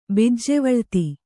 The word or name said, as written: ಬಿಜ್ಜೆವಳ್ತಿ